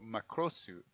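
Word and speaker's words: Makrosu (マクロス): And they changed it to Macross, which is pronounced very similarly. So Makrosu.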